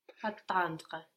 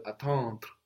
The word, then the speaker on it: attendre: Attendre. – Attendre. So emphasizing much more the EN, no, and the accent does fall there as well, will help you fall into the next sound. You're concentrating on the next sound, no? The D-R-E, which is making you pronounce it too much.